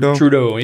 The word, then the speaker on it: Trudeau: Did I say his name right? Trudeau. – Yeah, ain't right.